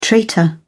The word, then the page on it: traitor: British English